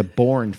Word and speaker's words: Boorn: I've seen it spelled mostly B-O-O-R-N. Sometimes it's spelled B-O-U-R-N, although that might be a modernization of the spelling there. So let's go with, doesn't matter, I'm going to pronounce it the same way.